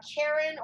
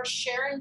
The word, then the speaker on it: Charon: And Charon, or Charon, depending on how you want to pronounce it, is Pluto.